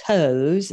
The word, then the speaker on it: Toews: And her name is Miriam Toews. It's T-O-E-W-S, but my hockey fan husband assures me that that's the way it's pronounced because Toews is a big hockey name.